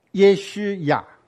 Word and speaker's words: 耶稣亚: 耶稣亚，好，那个一勾一点，那个念作乌，耶稣亚，所以耶稣亚这个字在旧约就有了，旧约的字根里面有好几个字都从这个字出来，耶稣亚是从这个字出来的，以赛亚是从这个字出来的，所以以赛亚跟耶稣亚都是预表。